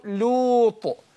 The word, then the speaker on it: لوط: في كتير بنطول لوط لو لو لو دي لا رغم ان هي مضمومه صح لكن هي انحراف اللام نفسه في النطق ده هو ده الخطا اللي ايه بيقع فيه كثير من الناس. فيقول ايه فامن له لوط لو لو دي لا لو مش لو لو هنا يقولون ماشي.